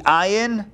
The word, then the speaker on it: Ayin: Well, if you were to write out all three letters, Ayin is written out, the Ayin is one letter, is one character, but the way you pronounce it is A-Y-N, Ayin, so it's comprised of three letters, the Ayin, the Yud, and the final Nun.